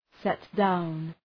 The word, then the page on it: set-down: ‘setdaʋn